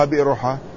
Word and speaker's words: بيرحاء: لا ما فيها همزة، ما في بئر، لا هي بير، يا إما أن نقول بيرحاء أو بيرحاء، ما فيها بئر، لا لأن أرض ليست بئرا، هي النار العامة تنطقها بيرحاء، لكن الواقع اسمها بيرحاء، يا بيرحاء يا بيرحاء.